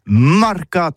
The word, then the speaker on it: marcat: Eh bien, d'ici à toutis, le mercat, de l'Uni, ou le mercat des rebelles, mais en gascogne toulousaine, c'est prononcé sur les marcat, avec un A,